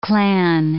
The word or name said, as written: clan